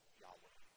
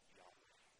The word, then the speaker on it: Yahweh: We don't really know how it's pronounced, but we often say Yahweh. Yahweh.